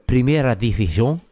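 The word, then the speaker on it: Primera División: Eh, maar laten we beginnen met de herstart in de Primera Division. Zeg ik dat goed? Is dat goed? mooi Spaans? Ja. Ja, prima.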